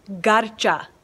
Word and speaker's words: Garcha: And the last name is more with a UH than an A. It's Garcha. Garcha. Neetu Garcha.